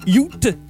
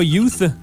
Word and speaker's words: Ute: Oui, je prononce bien Ute, U-T-E, et non pas Youth. C'est pour les gens qui, comme moi, qui se réécoutent et disent « J'espère que les gens pensent pas que je prononce mal Youth. » Non, c'est Ute.